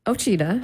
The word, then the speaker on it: Ochita: And two, even though I've known you for like 20 years, I'm not really sure how to say it. Ochita? – Ochita. – Oh, it is Ochita.